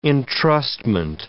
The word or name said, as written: entrustment